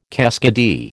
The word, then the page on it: keskidee: American English